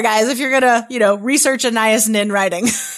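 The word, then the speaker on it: Anaïs Nin: Anais Nin.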